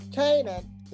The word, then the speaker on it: Canaan: Canaan.